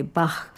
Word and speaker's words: Bach: Esta Bachiana, porque la che de Bach se pronuncia con sonido K, tiene la estructura de un aria da capo, ABA.